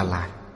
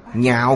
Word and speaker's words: nhạo: Chữ này đọc là nhạo, chữ không đọc là lạc. Nhạo nghĩa là yêu thích, lạc là khoái lạc. Chữ này ở đây đọc là nhạo.